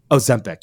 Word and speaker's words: Ozempic: Okay, the other things I want to chat about, I know you've got to go in a couple minutes, is this Ozempa or whatever that drug is. Ozempic, yeah.